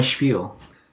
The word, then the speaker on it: spiel: I have to read my spiel. – Spiel. How do you spell that? – S-P-I-E-L. – Spiel? But it's pronounced spiel? – Mm-hmm.